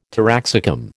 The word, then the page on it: taraxacum: American English